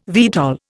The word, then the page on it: VTOL: Indian